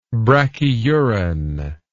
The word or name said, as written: brachyuran